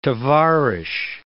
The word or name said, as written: tovarich